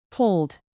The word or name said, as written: polled